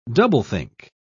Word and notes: doublethink: British English

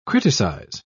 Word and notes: criticise: British English

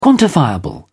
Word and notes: quantifiable: British English

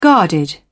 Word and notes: guarded: British English

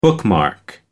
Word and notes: bookmark: American English

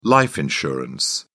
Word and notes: life insurance: British English

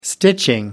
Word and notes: stitching: British English

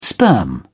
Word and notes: sperm: British English

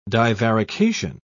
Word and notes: divarication: British English

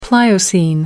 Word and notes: pliocene: American English

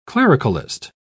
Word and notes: clericalist: British English

